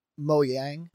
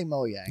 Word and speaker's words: Mojang: Didn't I pronounce it with a hard J. – Was it Mojang? – So I love to do this. – A lot of people say the creators probably say Mojang. – Yang, probably.